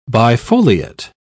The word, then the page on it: bifoliate: British English